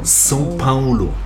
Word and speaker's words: São Paulo: Vielleicht auch noch ganz interessant, weil das, wir sagen ja mal bei uns im Deutschen São Paulo, na, als Stadt. Aber ausgesprochen wird es São Paulo. – Ja, das genau. – Das ist sehr nasal.